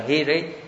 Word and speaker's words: hiri: Tam tâm sở hi ri chê ta si ca, chữ này đọc là hi ri. Tam là tiếng, tiếng Hán. Hi ri tiếng Pali.